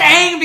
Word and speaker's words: Aang: Oh, it's pronounced Aang because the person who created Aang says it's pronounced Aang.